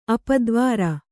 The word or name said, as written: ಅಪದ್ವಾರ